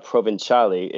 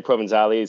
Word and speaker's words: Provenzale: Well, if you were listening to all the podcasts and hearing, you know, the sponsored advertisements in the middle of the segments, it's pronounced pretty well, actually, with a Provenzale is, you know, the proper way, I would think, to say it. But everybody just, you know, you could say Provenzale is kind of the Americanized version of it now.